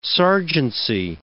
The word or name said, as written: sergeancy